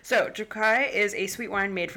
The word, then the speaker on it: Tokaji: Which I pronounced as Tokaji for literally years because I think it sounds better that way. But see, I was wanting to be like Tokaj. – It's technically Tokai. – Kai. – Yeah. – Yes. – Like with the toe in there. – I was reading about it and I saw it spelt with a K-A-Y.